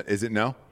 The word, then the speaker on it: Ngo: Is it no? Yeah. Okay, so the G is silent? – He's like a reporter, right?